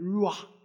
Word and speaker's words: Ruach: And why do you have to go, I said, because that's the way Jewish people pronounce it. Ruach.